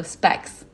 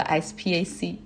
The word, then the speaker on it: SPAC: 这个一般三个字母或者中间它带着那个点儿的就可以读字母哈，然后一般是连着写的话就要读成一个词儿，比如说 specs，而不是我之前说的 SPAC。